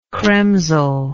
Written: chremzel

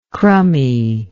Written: crumminess

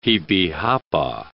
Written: jipijapa

